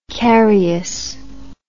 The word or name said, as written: carius